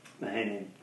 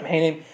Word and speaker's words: Mahanaim: So Mahanaim, I think I'm saying that right, Mahanaim? Mahanaim? – Mahanaim. – Yep. Mahanaim, that actually means two companies, so two camps or double camp.